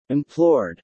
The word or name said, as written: implored